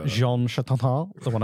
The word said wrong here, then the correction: Jean Chrétien: Jean Chrétien. – Jean Chrétien.